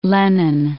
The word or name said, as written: Lenin Peak